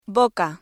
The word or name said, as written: boca